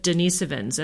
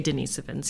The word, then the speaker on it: Denisovans: So, I want to start with Denisovans. And, uh, am I saying that right? – Yes, you are. – Okay. Because I've heard m- many people say Denisovans, other things. So we're going to say Denisovans here.